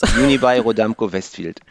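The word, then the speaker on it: Unibail-Rodamco-Westfield: Unibail Rodamco-Westfield.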